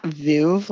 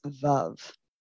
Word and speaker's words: Veuve: Which I looked up the pronunciation of Veuve, and it's like, it's not Veuve like we've been saying, it's Veuve.